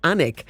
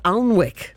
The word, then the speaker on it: Alnwick: So instead of saying Annick, she says Alnwick. The Alnwick Castle, Annick Castle.